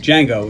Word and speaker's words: Django: Django. It's just Django. The D is silent.